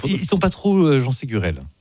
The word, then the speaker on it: Ségurel: Vas-y, t'as écouté Ségurel. – Oui, il est prononcé comme ça, effectivement.